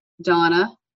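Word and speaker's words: Dona: And my email address is mayor, M-A-Y-O-R, dot Donna, and my name is spelled with one N, D-O-N-A, okay.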